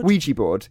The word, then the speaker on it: Ouija: Ouija board.